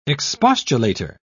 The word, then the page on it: expostulator: British English